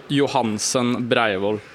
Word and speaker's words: Johansson Breivold: Hur var det du uttalade själv då? – Ja, det var bra. Jag säger Johansson Breivold.